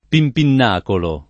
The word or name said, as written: pimpinnacolo